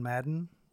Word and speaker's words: Madden: It rhymes with fadden. – that's the word you came up with. – I have been playing John Madden, the 20th edition.